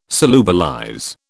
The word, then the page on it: solubilize: British English